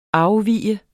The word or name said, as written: afvig